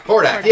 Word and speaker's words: Hordak: Hordak. – Hordak, yeah.